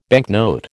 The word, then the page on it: banknote: American English